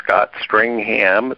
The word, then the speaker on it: Scott Stringham: A lot of people know me as Peter, but it's P, letter P, Scott Stringham. It's spelled just like it sounds, .com.